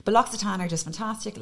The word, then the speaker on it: L'Occitane: L'Occitane, L'Occitane, L'Occitane. – I never know how to say it. – L'Occitane. – Okay. – It's a double, it's an X, a double C is an X in French.